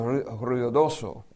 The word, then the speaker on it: Ruidoso: The word Ruidoso means noisy, but Ruidoso is the way you pronounce it in Spanish.